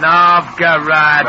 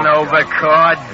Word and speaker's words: Novgorod: Novgorod, not Novgorod.